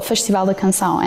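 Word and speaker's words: Festival da Canção: I think it's pronounced the Festival de Canção. Am I saying that right? Tell us more about this particular show. – Yes, well, Festival de Canção.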